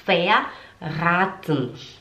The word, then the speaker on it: verraten: Nesse caso aqui, o A ele é longo, fica verraten. Não é raten, é raten. É t um, t um. O E a gente não pronuncia, sabe? O verraten. A gente não fala esse ten, a gente fala verraten ok?